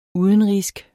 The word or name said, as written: udenrigsk